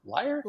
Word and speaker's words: lyre: She had a musical instrument that was stringed, and L-Y-R-E. I don't know how to pronounce it. Lyre? – Lyre. – What is it? – Lyre. That's how we pronounce it in music history anyway.